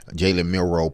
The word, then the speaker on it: Jalen Milroe: Jalen Milroe played and did what he needed to do as a quarterback to get Alabama that win.